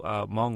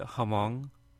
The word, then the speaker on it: Hmong: Because he pronounced it like, and I don't feel, I think this is a Hollywood thing, but I don't think anybody ever really pronounces it Hmong. Have you heard that before? – No, I've heard, before that I heard Hmong. – Right. So I've never heard anybody actually try to pronounce the H, which I don't, if, you know, the listener, you know, if a listener doesn't know what we're talking about, Hmong is spelled H-M-O-N-G.